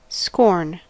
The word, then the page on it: scorn: American English